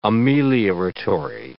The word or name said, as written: amelioratory